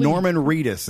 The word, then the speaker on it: Norman Reedus: Norman Reedus.